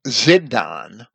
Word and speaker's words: Sidon: The name Sidon, which is often spelled with a Z, Zidon, and that's because it begins with the Hebrew letter Tsadi, which is a TS sound, that's very often transliterated into a Z, even sometimes in Greek, but usually into an S in Greek.